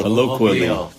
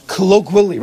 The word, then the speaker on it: colloquially: How do you say it? Colloquially. – Colloquially. – Right. There's a, there's a W. Q U. It's colloquial.